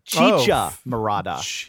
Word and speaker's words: chicha morada: I'm sorry. I mispronounced all the way through. Chicha morada.